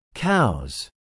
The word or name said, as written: cows